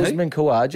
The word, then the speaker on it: Usman Khawaja: Usman Khawaja.